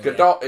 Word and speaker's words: Gadot: It's the whole Gal Gadot, Gal Gadot situation. – Gadot is correct. – I call Gadot.